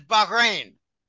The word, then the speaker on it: Bahrain: For instance, in Bahrain, and the correct pronunciation is Bahrain.